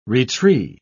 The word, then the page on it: retree: British English